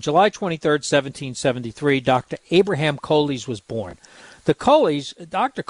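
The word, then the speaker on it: Colles: So his real the pronunciation should be Coley's.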